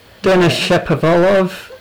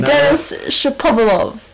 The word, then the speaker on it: Shapovalov: Denis Shapovalov. – That is not his name. That was the worst pronunciation of Shapovalov. – Shapovalov. – Not pop. – Oh, you're sad. – One P. Denis Shapovalov.